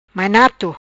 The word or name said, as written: Mainato